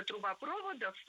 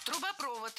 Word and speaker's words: трубопровод: Там проходит несколько трубопроводов по территории Косово, которые считаются стратегически важными. – Трубопровод. Неверно. Правильно, трубопровод. Ударение в этом слове ставим на последний слог и ориентируемся на исходный глагол. Проводит. Проводит трубопровод.